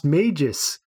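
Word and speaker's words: Mejis: It should be pronounced Magus."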